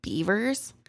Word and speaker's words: Bevers: Missy Bevers is how I think it's pronounced. I've heard it both. I've heard it Bevers or Beavers, but it's spelled like Bevers. So I'm just going to say Bevers.